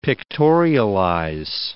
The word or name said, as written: pictorialize